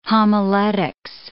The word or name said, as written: homiletics